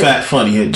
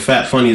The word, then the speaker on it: Fat Funny: Fat Funny. – Just Fat Funny? – Interview with Fat Funny is a show, but Fat Funny is my name because that's like...